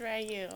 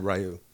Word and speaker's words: Ryu: It's Ryu. – Okay. Ryu. Shit. I was going Rue. – It's Korean. – Yeah. – You just embarrassed yourself. You need to apologize. – Richard, now hold on. How did you pronounce it? – I said Ryu. – Like Ryu?